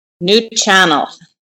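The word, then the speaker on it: Nuu-chah-nulth: Did I say that properly? – Nuchanult. – Nuchanult. Try again. How do you say it? – Nuchanult.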